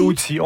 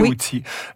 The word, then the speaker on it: Houthis: Parlez-nous de, est-ce que je prononce bien les Houthis? – Les Houthis, on dit les Houthis. Houthis, mais je pense, j'ai pas vérifié l'arabe, mais en tout cas, dans l'usage français, on parle des Houthis.